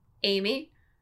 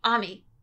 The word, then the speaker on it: Amy: But it's also, there's only been one time in my life where someone couldn't or wouldn't pronounce my name the way it was supposed to be pronounced, which is Amy. Because, like, and it wasn't that they couldn't. They wouldn't. And so they only called me Ami.